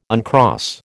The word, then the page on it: uncross: American English